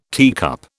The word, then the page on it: teacup: British English